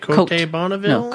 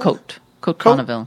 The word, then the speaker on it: Côte Bonneville: We have Zane and Wiley's Steakhouse Winemaker Dinner with How do you say it? Côte Bonneville? – No, Côte Bonneville.